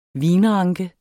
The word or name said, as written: vinranke